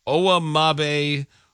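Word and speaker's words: Owamagbe: Okay, according to this, it is pronounced Owa Mabe Odigizua.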